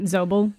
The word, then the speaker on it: Zobel: Zobel? – I think so. That's how I was pronouncing like it. Zobel. Yeah, it's like Z-O-B-E-L.